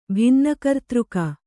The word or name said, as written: ಭಿನ್ನಕರ್ತೃಕ